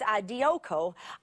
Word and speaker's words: dioko: I press on two different times in this segment of scripture, and it is a word that means, it's dioko, it's how you would say the word. If you like to write down words like this, it's Greek D-I-O-K-O. Dioko, has two long O's there.